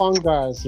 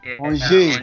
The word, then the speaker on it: Angers: And then please help me with the pronunciation for Angers, not Angers, right? – Angers.